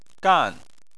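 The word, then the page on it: gan: tone 4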